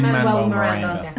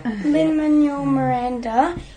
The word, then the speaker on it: Lin-Manuel Miranda: Lin-Manuel Miranda. – Lin-Manuel Miranda.